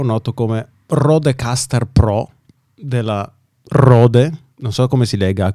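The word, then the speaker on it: Røde: Quindi secondo me è tutta una farsa e si legge Rode, che è una...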